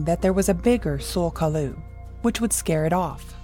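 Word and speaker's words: Tsul'kalu: Sul Kalu was the common pronunciation that I found during my research, but it may also be pronounced Jul Kalu.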